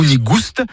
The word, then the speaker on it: nigoust: Nigou nigoust, avec prononciation du S originel que l'on retrouve dans le provençal goustus, goûteux, et qui a été remplacé depuis par l'accent circonflexe dans notre orthographe moderne.